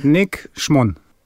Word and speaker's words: Nick Schmon: Is it like Schmon? Like, how would it rhyme with? I know you said on. Turn it on. – Okay, so Schmon. It's more like on as opposed to on.